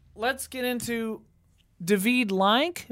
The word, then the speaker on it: David Lynch: Yeah, but I'm pretty sure it's David Lynch. – I think it's David Lynch.